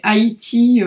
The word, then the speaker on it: Ayiti: Mais Haïti, en fait, ça se prononce comme le pays Haïti. Ça s'écrit A-Y-I-T-I. Mais c'est bien Haïti, le pays.